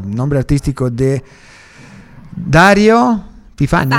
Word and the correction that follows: Faini: Faini. – Ah, Faini, perdón. Dario Faini, ok.